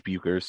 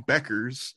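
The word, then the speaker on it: Bueckers: That is kind of how it's spelled, but the correct answer is Satchel Paige Beckers. But yes, it is spelled with that U in there.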